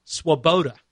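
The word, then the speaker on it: Svoboda: Don't even spell it because the V, I guess, is pronounced as a W. So it's Swoboda.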